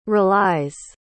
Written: relies

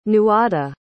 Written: nuada

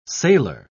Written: sailer

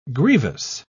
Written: grievous

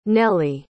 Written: nellie

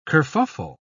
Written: kerfuffle

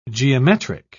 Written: geometric

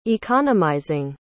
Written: economizing

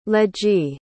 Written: ledgy

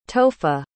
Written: tohfa

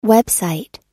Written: website

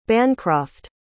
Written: bancroft

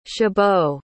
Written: Chabot

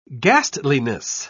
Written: ghastliness

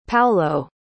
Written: Paulo

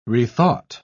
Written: rethought

